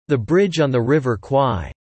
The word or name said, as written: The Bridge on the River Kwai